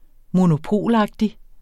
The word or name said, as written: monopolagtig